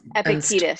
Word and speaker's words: Epictetus: what's with uh, Dr. Laurie Santos and the agent, um, is it Epticus? Is that how you say it? Oh, um, Epictetus.